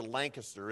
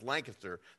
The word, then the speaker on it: Lancaster: Just like the proper pronunciation for Lancaster is Lancaster, not Lancaster, okay?